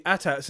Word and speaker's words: AT-AT: AT-AT. Is that how you pronounce it? – Yes, it is. It's an AT-AT.